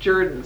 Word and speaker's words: Jordan: I guess you're supposed to pronounce it Jordan, some southerner told me.